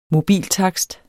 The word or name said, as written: mobiltakst